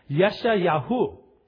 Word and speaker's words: Yeshayahu: But in the Hebrew, it's properly pronounced Yeshayahu, right? Now, if you listen to that, that sounds it sounds like nothing like Isaiah, right?